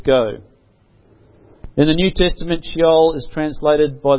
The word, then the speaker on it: Sheol: The Old Testament Hebrew word for hell is Sheol. I don't know if I'm pronouncing that right. S-H-E-O-L.